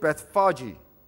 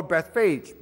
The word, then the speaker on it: Bethphage: First, the Bible tells us that when he came nigh to Jerusalem unto Bethphage, it can be pronounced two ways. The way that Elder Cheong had pronounced it, Bethphage or Bethphage, both are acceptable.